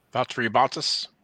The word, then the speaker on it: Valtteri Bottas: Valtteri Bottas.